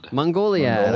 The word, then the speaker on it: Mongoliad: Mongoliad. – I was getting the wrong emphasis on the wrong syllable.